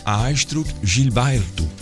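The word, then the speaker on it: Astrud Gilberto: Eigenlijk moet je haar naam anders uitspreken. Astrid Gilberto, zeggen ze in Brazilië.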